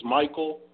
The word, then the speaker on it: Mykal: I'm pretty sure the name is Michael, but it's spelled Michael like Michael.